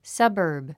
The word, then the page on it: suburb: sʌ́bəː r b; サバーブ